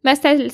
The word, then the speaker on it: Mastel: Mastel, ça s'écrit M-A-S-T-E-L et ça est la contraction de mes deux noms de famille.